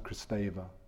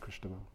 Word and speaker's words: Kristeva: Julia Kristeva, it's often pronounced Kristeva, but it's pronounced Kristeva.